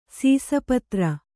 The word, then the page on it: ಸೀಸಪತ್ರ: Kannada